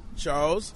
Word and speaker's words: Charles: Charles, C-H-A-R-L-E-S, Johnson, J-O-H-N-S-O-N.